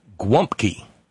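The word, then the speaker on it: gołąbki: As far as Polish dishes, James in Illinois, Gwumpki. Not sure on the spelling, but it's pronounced Gwumpki, stuffed cabbage with rice and pork, I think.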